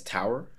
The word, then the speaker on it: tarot: It's Tarot. – Tarot. T-A-R-O-T. Tarot. It's Tarot. It's pronounced weird.